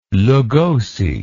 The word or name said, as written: Lugosi